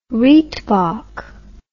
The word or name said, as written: reitbok